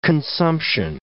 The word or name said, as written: consumption